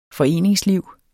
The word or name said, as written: foreningsliv